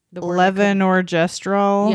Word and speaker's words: levonorgestrel: It's one dose of the levonorgestrel yeah.